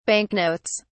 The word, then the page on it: banknotes: British English